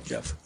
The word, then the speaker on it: Jeff: I'm Jeff Cycles, J-E-F-F. – I'm sorry, Jeff. I apologize. – Jeff Cycles, J-E-F-F, last name C-I-C-H-O-S-Z.